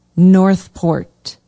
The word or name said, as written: northport